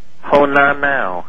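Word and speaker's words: Honaunau: H-O-N... – A-U-N-A-U. – N-A-U. That's a weird name. And you pronounce that... – Honanau.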